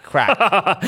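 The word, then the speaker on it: Krack: We've got Alonso, who of course has moved over, and our buddy Mike Crack. Crack. I will say it correctly. Mike Crack.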